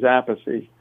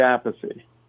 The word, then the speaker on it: Appice: The name my father pronounced is Appice. – Okay. – And Carmine used to say Appice. And then when he played with Rod Stewart, Rod Stewart pronounced it a piece. He said, that's easier, whatever. So he became Carmine a piece, and I stayed with Vinny Appice.